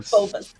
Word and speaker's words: Phobos: How do you pronounce that? – Phobos. – Phobos.